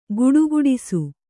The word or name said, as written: ಘುಡುಘುಡಿಸು